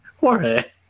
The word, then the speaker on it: Jorge: Jorge. It's a silent G.